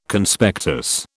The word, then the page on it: Conspectus: British English